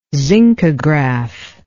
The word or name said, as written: zincograph